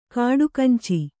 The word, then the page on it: ಗಡಗಂಚಿ: gaḍaganci